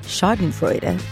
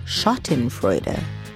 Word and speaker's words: Schottenfreude: You want to think I'm saying Schadenfreude, but I'm actually not. The book's title is Schottenfreude, German words for the human condition.